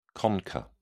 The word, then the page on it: concha: British English